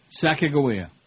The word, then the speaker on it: Sacagawea: You are pronouncing it right. – Sacagawea.